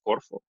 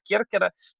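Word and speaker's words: Керкера (Κέρκυρα): на самом деле греки не говорят Корфу, греки не произносят этого слова, да, они говорят Керкера, это их название.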